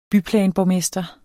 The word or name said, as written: byplanborgmester